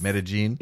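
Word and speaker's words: Medellín: Medellin, the proper way to say it, in Colombia.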